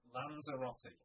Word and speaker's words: Lanzarote: Lanzarote.